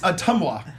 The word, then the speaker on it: Ottumwa: I'm sorry. Is it Iowa? – It's Ottumwa. You live.